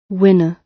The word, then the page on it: winner: British English